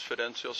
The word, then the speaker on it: Fidencio: It's Fidencio, spelled F as in Frank, I-D, E-N-C-I-O. Last name is Zepeda, spelled Z-E-P-E-D-A.